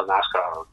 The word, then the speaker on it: Nazca: Io ho visto che in Perù usano con la C, con la S, scusa, non con la Z, però insomma si pronuncia Nazca, pronunciano come vuoi, è giusto.